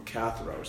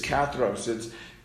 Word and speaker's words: katharos: Those who, the word karthos, and I might have just pronounced that wrong, katharos, sorry, I misplaced an R. In Greek, it's katharos.